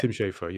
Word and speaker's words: Schafer: I keep on trying to make sure I'm getting his name right. It's Tim Schafer, right? – Tim Schafer, yeah. Well, at least I've been saying Tim Schafer.